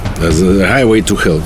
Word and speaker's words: Highway to Hell: Да, моя песня сегодня, это песня великой группы ACDC «Heavy to Hell». Highway to Hell.